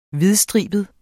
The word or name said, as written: hvidstribet